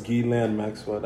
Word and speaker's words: Ghislaine: Ghislaine Maxwell, that's how you say it.